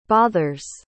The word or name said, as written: bothers